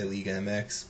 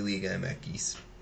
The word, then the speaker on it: Liga MX: Is it Liga MX or is it Liga Mekis? – It's Mekis. I'm American and I'll butcher. It's because Spanish X is Equis. So, I got you. – So I butcher it and I say Liga MX, but whatever. – Because I hear Daryl Groves say Liga Mekis all the time. – Yeah, it's Liga. It's technically Liga Mekis.